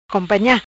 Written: Companha

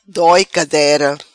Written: Doi Cadera

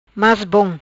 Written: Mas bom

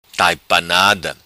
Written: Taipanada

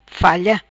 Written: falha

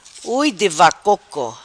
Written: ui di vacoco

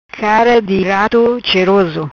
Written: Cara di rato

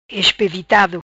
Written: Espevitado

